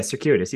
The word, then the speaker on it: circuitous: Is that the right way to say that word? – Yeah, circuitous. You got it.